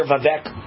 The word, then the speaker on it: Vivek: And sure enough, Trump was asked on the Glenn Beck show today, would he consider Vivek, I believe that's how you pronounce it, rhymes with cake, Vivek Ramaswamy as his vice president.